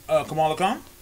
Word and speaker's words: Kamala Khan: Kamala Khan.